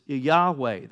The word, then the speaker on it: Yahweh: Now, some traditions have called it Je- the name of Jehovah, same thing, but, but Hebrew scholars would, would tell you that the J, pronouncing it Jehovah, is not proper, but it's Yahweh.